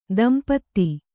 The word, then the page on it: ದಂಪತ್ತು: kannada